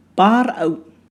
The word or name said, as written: pāra'u